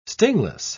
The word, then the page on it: stingless: British English